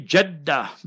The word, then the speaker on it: Jeddah: You see, Hafizab, you pronounced it Jiddah. So remember, you must say Jaddah, bifathil jim, with the zabar on the jim.